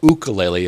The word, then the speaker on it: ukulele: So they started off with a kid who did a little ukulele playing, or ukulele is how they pronounce it.